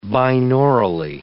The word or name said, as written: binaurally